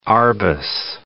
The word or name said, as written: Arbus